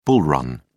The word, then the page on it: bull run: British English